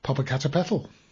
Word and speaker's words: Popocatépetl: Congratulations again on your pronunciation of Popocatépetl.